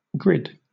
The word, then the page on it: grid: British English